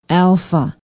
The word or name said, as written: alpha